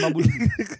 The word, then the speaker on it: Mambululu: Nee, het is Mambululu. Nee, ik heb gewoon één te veel Lu.